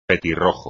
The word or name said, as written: petirrojo